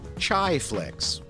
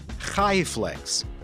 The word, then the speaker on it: Chaiflicks: When a streaming service has a brand spelled Chaiflicks, some might pronounce it as Chaiflicks, which might be movies for, I don't know, lovers of flavored tea. But if you're Jewish, you're likely to pronounce the brand Chaiflicks, Chai being the Hebrew word for life.